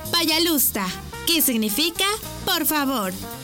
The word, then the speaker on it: пожалуйста: Y se dice пожалуйста. ¿Cómo? Пожалуйста. Una vez más. Пожалуйста. Y se escribe пожалуйста. P-A-Y-A-L-U-S-T-A.